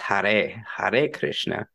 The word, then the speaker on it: Hare Krishna: First of all, let me correct myself, and I gotta fucking keep saying it correctly. It's Hare Krishna.